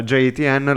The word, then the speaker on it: JETN: JTN, uh, eh, JTN, la pronuncia corretta.